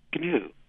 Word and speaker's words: gnu: Oh, gnu. – Yeah, but the G is silent.